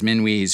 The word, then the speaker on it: Minuit: A remarkable one stepped forward, a man named Pieter Minuit, spelled M-I-N-U-I-T. German was Minuit's first language, and Dutch was his second, but his ancestry was French. Shorto suggests that he probably pronounced his last name the French way, Minuit, as I will do, because Shorto certainly knows a lot more than I do.